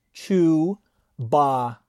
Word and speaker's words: Chewbacca: Chewbacca.